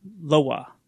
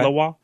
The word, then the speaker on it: lwa: They also believe in many other spirits called Loa, Loa, mm-hmm. L-W-A, mm-hmm. – L-O-A-S. They pronounce it L-W-A, Loa. – Okay. – Loa.